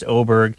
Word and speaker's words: Åberg: But yeah, so his name is spelled A-B-E-R-G. Now, he's Swedish, so it's an A with a little letter O or something over top of it, but it's pronounced Åberg.